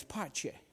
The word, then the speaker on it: pace: Now, peace in Italian is pace. I said pesce, which is fish.